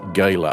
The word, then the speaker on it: Gala: They pronounce it Gala up there in the northeast of England, a large annual gathering associated very much with the coal mining heritage of the region and known colloquially as the Big Meeting.